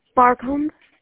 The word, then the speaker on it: Barcombe: Barcombe. – Can you spell that for me, please? – It's like the word bar where you go drinking and a comb for your hair.